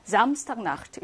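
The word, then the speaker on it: Samstagnacht: 강세는 당연히 앞에 있는 명사에 오겠지. Samstag Nacht. 이렇게 발음을 합니다.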